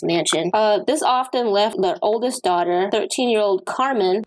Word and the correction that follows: Karmein: I think I'm saying that right, K-A-R-M-E-I-N, or Carmaine, Carmaine, like low main.